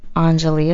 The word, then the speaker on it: Anjali: It's Priya Anjali Rai. – How do you spell Priya? – Rai. – It's Priya, P-R-I-Y-A. Mm-hmm. Anjali is spelled A-N-J-A-L-I, last name Rai, R-A-I.